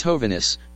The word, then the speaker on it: Hovhannes: However, Hovhannes' daughter Jean Nandi states in her book Unconventional Wisdom that my father's name at the time of my birth was Hovhannes, pronounced with accent on the first syllable.